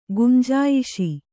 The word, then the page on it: ಗುಂಜಾಯಿಷಿ: gunjāyiṣi